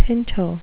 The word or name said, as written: pinto